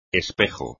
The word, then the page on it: espejo: espagnol